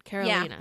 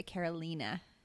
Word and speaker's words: Carolina: Not like the state. Carolina. – Yeah, that's the way her mom pronounced it anyway, Carolina. – Yeah. – Which is not the way I have been saying her name for 20 years.